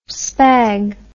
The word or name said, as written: spag